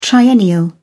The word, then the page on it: triennial: British English